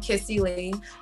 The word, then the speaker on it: Kissie: Instagram and Twitter, it's I am Kissy Lee. And my name is spelled K-I-S-S-I-E.